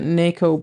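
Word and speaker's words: Nacoe: The defendant was, I think you pronounce it Naco Brown. It's N-A-C-O-E.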